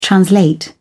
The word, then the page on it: translate: British English